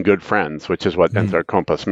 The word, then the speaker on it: Entrecompas: The last one is Entrecompas is the correct way to pronounce it. My man, Nick Herrera, tells me.